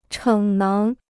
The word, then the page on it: 逞能: chěng néng